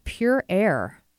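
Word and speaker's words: Pure Ayre: And then a new partner starting next week is Pure Air. A-Y-R-E is how you spell that.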